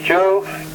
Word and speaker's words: Joe: My name here is Joe, Juliet Oscar Echo, up here in Plainfield.